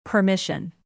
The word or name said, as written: permission